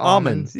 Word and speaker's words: almonds: You don't pronounce the L, right? – Oh, what do you say? – It's almonds. Yeah, it's almonds.